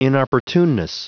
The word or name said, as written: inopportuneness